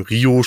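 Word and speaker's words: Riho: Äh, Ryo gegen äh, Rio. Nicht Ryo, Rio spricht man sie aus gegen Nyla Rose.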